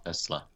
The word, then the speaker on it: Tesla: He's a multi-billionaire, but at the very least, he's putting his money into productive things like SpaceX and Tulsa. Well, at the same time, Tesla. – Tesla. Yeah.